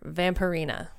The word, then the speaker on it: Vampirina: It's vampire and ballerina mixed together. Vampirina.